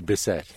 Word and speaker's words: Bisset: Apparently, I checked this out. It's pronounced Bisset.